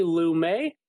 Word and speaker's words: Lumet: Sidney Lumet, hopefully I'm pronouncing that right.